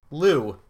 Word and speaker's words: Lew: Lou. L-E-W. Well, it's Lev, but it's the Americans pronounce it Lou.